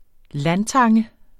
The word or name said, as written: LANDTANGEN